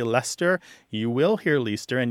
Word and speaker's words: Leicester: So a word that's spelled L-E-I-C-E-S-T-E-R did used to be pronounced something more like Leicester. Leicester. – Yeah. – But there were three syllables, and now it tends to be two, okay? Because the two syllables that sound roughly the same, the E-I-C and the E-S-T combine to make one sound.